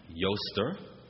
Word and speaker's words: Eostre: It comes from a word, yoster, and if you were to look this up, it's spelled E-O-S-T-R-E, yoster, or ostara, O-S-T-A-R-A.